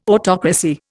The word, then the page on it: autocracy: Indian